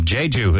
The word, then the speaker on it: Jeju: Jeju, is that how you pronounce it? – Yeah, Jeju-do.